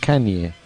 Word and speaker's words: Kanye: Kanye.